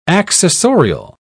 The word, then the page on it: accessorial: British English